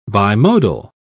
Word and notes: bimodal: British English

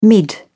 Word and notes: mid: British English

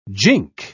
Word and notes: jink: British English

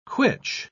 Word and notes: quitch: British English

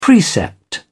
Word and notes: precept: British English

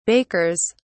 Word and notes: bakers: British English

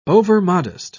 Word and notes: overmodest: British English

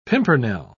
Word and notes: pimpernel: British English

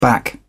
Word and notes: back up: British English

